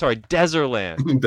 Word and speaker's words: Deserland: Deezerland. Deserland. Thank you. Thank you for giving me the correct pronunciation on that. – Just think of a desert, because it was like a desert before Deserland.